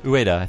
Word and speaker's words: Ueda: did I say his name right? – Ueda. Ueda seems to understand that.